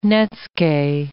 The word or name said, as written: netsuke